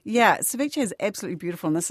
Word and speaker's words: ceviche: Ceviche, I'm never sure of the pronunciation. It has the E on the end, I think. – Yes, ceviche. Yeah.